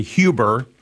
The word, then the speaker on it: Huber: A lot of people pronounce it Huber. The Germans pronounce it the H-U-B-E-R as Huber.